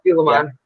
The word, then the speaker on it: Dulaman: Which the next song is, and I don't know if I'm saying this right, Dulaman. – Is yeah, that, Dulaman.